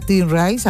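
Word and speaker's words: Tim Ries: Allí el saxofonista Tim Rice, a ver si lo, creo que lo he dicho bien. – Sí, sí, perfecto.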